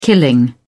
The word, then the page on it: killing: British English